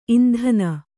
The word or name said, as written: ಇಂಧನಿ